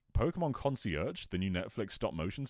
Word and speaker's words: Concierge: Concierge.